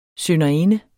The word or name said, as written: synderinde